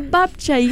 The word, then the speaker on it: babcia: You're like a babcia, yes.